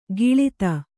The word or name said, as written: ಕಳಾದ